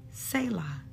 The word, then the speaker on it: Selah: You notice that as I read Psalms 46, at the end of a few of the verses, was the word Selah, or is pronounced Selah. It's S-E-L-A-H.